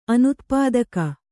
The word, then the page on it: ಅನುತ್ಪಾದಕ: kannada